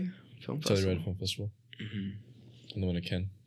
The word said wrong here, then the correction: Cannes: Ken. Is that? – Oh, Cannes. Yeah.